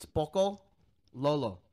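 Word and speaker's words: pakalolo: You're giving way too much emphasis on the O at the end. – Okay. – It's Pocololo. All one word.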